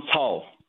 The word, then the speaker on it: Cao: Is it Pastor Cao? – Yeah, Pastor John Cao.